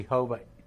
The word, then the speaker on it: Jehovah: This is pronounced E. This is Ho, V, A. E, Ho, V, A. – Okay. – Jehovah, Jehovah. – Uh-huh. – That's the way we say it today.